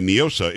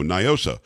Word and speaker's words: NIOSA: Now, some people are trying to pronounce it the Spanish way, NIOSA. It's not a Spanish word. It's an English acronym for Night in Old San Antonio, NIOSA.